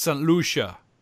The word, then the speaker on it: St. Lucia: Oh, sorry. Pronunciation correction. It's St. Lucia.